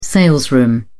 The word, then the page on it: salesroom: British English